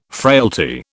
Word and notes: frailty: British English

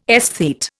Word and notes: aesthete: Indian

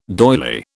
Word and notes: doyley: British English

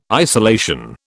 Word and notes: isolation: British English

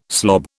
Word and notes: slob: British English

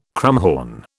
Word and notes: krummhorn: British English

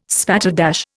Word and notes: spatterdash: Indian

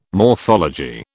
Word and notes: morphology: British English

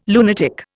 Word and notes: lunatic: Indian

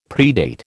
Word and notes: predate: American English

